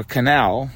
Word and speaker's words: Kanal: Tony Kanal or Canal, but it's spelled with a K.